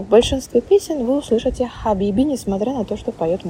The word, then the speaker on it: хабиби: Эту букву очень легко произносить, но очень трудно распознавать. Вы же 100% знаете одно слово с этой буквой, с этим звуком. Это слово, которое вы часто слышали в песнях арабских – «хабиби», что означает «дорогой» или «возлюбленный».